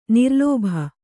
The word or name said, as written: ನಿರ್ಲೋಭಿ